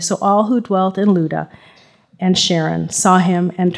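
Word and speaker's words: Lydda: Okay, so this is Peter's little trek here from Jerusalem over to, however you pronounce that, in Greek, it's pronounced Luda.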